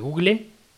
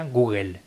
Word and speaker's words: Google: Se escribe Google, se pronuncia Google, se invierten las E y la L a la hora de pronunciarlo. – Y en todo caso los norteamericanos pueden decir Google, pero no Google.